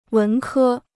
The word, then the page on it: 文科: wén kē